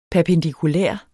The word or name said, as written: perpendikulær